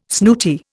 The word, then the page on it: snooty: Indian